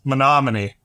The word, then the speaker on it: Menomonee: He's the CEO of Rocket Clicks, uh, which is a hyper focused digital marketing and advertising agency located in Great my pronunciation, Menomonee Falls, Wisconsin. Yeah, pretty close. Menomonee. Menomonee. There we go.